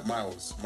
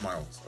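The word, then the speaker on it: Myles: But obviously, guys, it's your boy Miles. My name's Miles, okay? – Miles.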